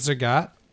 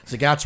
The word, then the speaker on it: Zagat: That's right. Zagat rhymes with cat. Zagat rhymes with cats.